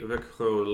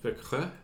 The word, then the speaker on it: Växjö: Well, that about does it for our team of the week, the Växjö Lakers. And that's a nice pronunciation. – Växjö? – Yeah.